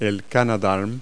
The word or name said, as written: canadarm